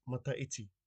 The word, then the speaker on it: Mataiti: The first word is mata iti. This is year in English. Mata iti is spelt M-A-T-A-I-T-I.